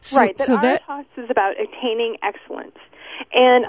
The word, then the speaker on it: aretas: This is the Greek word you found, aretas, pronounced like gravitas, A-R-E-T-A-S.